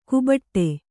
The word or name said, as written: ಕೊಬ್ಬಟ್ಟು